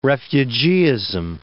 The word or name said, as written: refugeeism